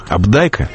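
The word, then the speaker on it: Апдайк: Когда в 1965 году на нас обрушился «Кентавр», шедевр «Абдайка», с тех пор я привык к ударению на втором слоге, этот роман мгновенно стал культовым, книгой-паролем, позволяющей попасть в заветный клуб понимающих на кухне тех интеллигентных домов, где рождалось общественное мнение 60-х.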